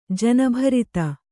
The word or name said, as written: ಜನಭರಿತ